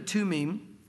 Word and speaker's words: Tumim: And so there should be those lights and perfections in the Spirit, so to speak, the Urim and the Tumim. And again, they don't pronounce their TH as th, it's like a T sound, so that's why I'm saying it that way.